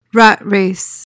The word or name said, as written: rat race